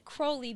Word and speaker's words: Crowley: Well, he said Crowley because it rhymes with holy.